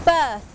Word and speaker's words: berth: เบิร์ธ BERTH birth อ่านออกเสียงเหมือนกันกับคำว่า birth BIRTH